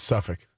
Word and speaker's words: Suffolk: I think it's Suffolk. – Yeah. yeah I think the L is silent.